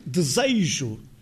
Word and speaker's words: desejo: Esse I, não escrito, mas pronunciado, acha-se igualmente em desejo, que rima com beijo, com igreja, com fecho e desfecho, em que não está um I escrito.